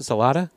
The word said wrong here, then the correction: Soliday: Soliday. – Soliday, I'm sorry.